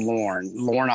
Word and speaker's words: Lorne: We're sitting on the deck of a beautiful house that overlooks a place called Lorne, Australia.